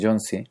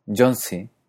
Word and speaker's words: Jónsi: En islandés, cuando la I lleva acento, se pronuncia I como en español, pero cuando no lo lleva, como en Jónsi, se pronuncia similar a la E cerrada del francés I, como en mi, míos. I.